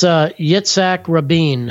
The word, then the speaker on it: Yitzhak Rabin: I'm probably butchering the name, but it's Yitzhak Rabin.